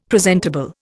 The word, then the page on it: presentable: Indian